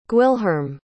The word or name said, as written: gwilherm